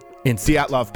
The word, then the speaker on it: Dyatlov: I'm not sure how to pronounce it. Dyatlov Pass. – Dyatlov Pass. Yep. – Dyatlov, is that how you pronounce it? – Okay.